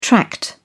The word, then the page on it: tract: British English